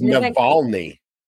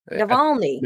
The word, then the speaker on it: Navalny: It's Navalny. Navalny.